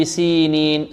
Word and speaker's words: سينين: هذا المبتدئين، المبتدئين هذا المبتدئين والذين يخطئون في هذه الكلمة، وإلا في المتمرس ينطق هكذا وطوري سينين سينين، لكن المبتدئ يركز عليها ويركز على أن هناك ياء مدية بعد السين هكذا وطوري سينين سينين سينين، والتين والزيتون وطوري سينين، وهذا البلد الأمين، وهذا الذال هنا ماذا؟